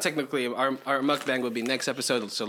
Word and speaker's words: mukbang: Mukbang. – How do you say bang? – Bang. – Okay, muck. – Muck. – Mukbang. – Mukbang.